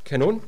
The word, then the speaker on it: kanon: This concept of canon, derived obviously from the Greek word kanon, with a long O,